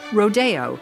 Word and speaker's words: Rodeo: DeMille pronounced the title of this very successful ballet, Rodeo. Copland pronounced it both ways. So there you have it. It's okay to pronounce it Rodeo.